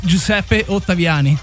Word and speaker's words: Giuseppe Ottaviani: What is the correct way to say it? – Correct. – It's Giuseppe Ottaviani.